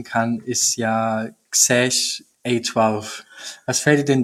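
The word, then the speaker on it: X Æ: Quasi, also XAE, also das griechische AE letzten Endes, also Xash wird das ausgesprochen, A12, das ist Elon Musks Kind.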